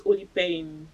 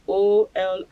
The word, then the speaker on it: Olipearl: My name is Olipearl. – Can you spell it? – Olipearl.